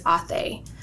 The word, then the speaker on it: Ate: Athe. So it looks like the word ate, like you ate something, but you pronounce it as Athe.